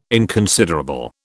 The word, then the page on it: inconsiderable: British English